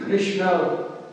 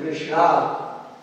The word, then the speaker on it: Krishna: Like Krishna is the correct pronunciation and Krishna is a wrong pronunciation.